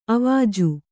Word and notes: ಆವಜ: āvaja

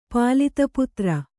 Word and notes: ಪಾಲಿತಪುತ್ರ: pālita putra

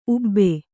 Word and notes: ಊಬು: ūbu

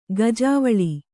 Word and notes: ಗಾಜುವಳೆ: gājuvaḷe

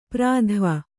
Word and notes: ಪರಿಧಾವಿ: pari dhāvi

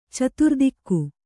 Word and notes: ಚತುರ್ದಿಕ್ಕು: Kannada

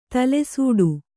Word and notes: ತಲೆಸೂಡು: tale sūḍu

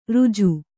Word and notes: ರುಜು: ruju

